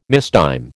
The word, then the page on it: mistime: American English